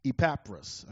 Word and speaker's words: Epaphras: It was one of his fellow apostles or co-workers named Epaphras. Epaphras, I guess, would be a better pronunciation.